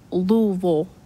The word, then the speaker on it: Louisville: They, anything that has a ville at the end, they say vol, like Louisville, Kentucky. It looks like Louisville or Louisville, but Louisville.